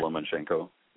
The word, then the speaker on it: Lomachenko: And I believe, is it Lomachenko? Am I pronouncing that right? – Lomachenko. Yeah.